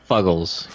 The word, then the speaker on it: Fuggles: And there's two notable ones, the Kent Goldings or Goldings, and the, how do you say that, Fugles or Fuggles? – Fuggles.